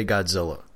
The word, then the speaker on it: Godzilla: I've heard the other people on Odo Island call him Gojira. – Nah, it's Godzilla. – Are you sure? – They're trying to say Godzilla.